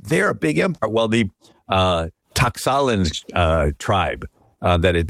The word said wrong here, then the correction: Tlaxcalan: been persecuted, and I know I'm not pronouncing that right, Tlaxcalan, D-L-A-X-C-A-L-A. – That's a tough one, man.